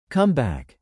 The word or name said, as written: comeback